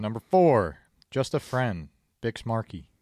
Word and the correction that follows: Biz Markie: Biz Markie.